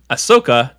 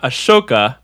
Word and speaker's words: Ahsoka: Today's beer is brought to you by Ahsoka, not Ashoka, like I thought.